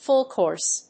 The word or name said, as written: full course